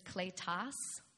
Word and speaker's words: kletos: And that word that's translated into called to belong in my translation, which is N-E-T, is what I use sometimes. The Greek word is kletos. And we have the Greek word. It's spelled, if you like to take notes and you want to know how to say it, K-L-E-T-O-S, kletos.